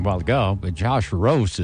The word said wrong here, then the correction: Ross: is what I was saying. It's Josh Ross.